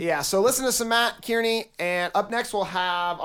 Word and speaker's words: Kearney: His name is Matt Carney or Matt Kearney. I don't really know how to say his name. – Oh, I like that so. – Guy. – Kearney? Carney? He's a Carney.